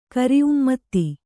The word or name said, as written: ಕರಿಉಮ್ಮತ್ತಿ